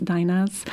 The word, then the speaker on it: dainas: D-A-I-N-A is how it's spelled. Is that Latvian? – Yes, it is. It is a Latvian term. In Lithuanian, it's dainos. In Latvia, it's dainas. And there's a wonderful story about how these all came to be.